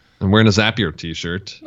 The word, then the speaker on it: Zapier: And just so you know, I like the fact that you said it correctly, Zapier, because if people say Zapier, it's Zapier because Zapier makes you happier.